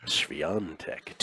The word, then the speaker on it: Świątek: Świątek. – To win. Yeah. Proper Polish pronunciation, pardon me. To win this. – No, you nailed it.